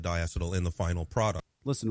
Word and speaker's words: diacetyl: It's fucking diacetyl.